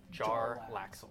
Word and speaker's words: Jarlaxle: And is it pronounced like Jarlaxle or Jalaxle? – Jarlaxle.